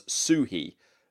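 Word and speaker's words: Suchý: Woodcraft from Delicious Games from Vladimir Suhi. Somebody had to correct me on the pronunciation. They said that the phonetic pronunciation was Suhi, like S-U-E-dash-he.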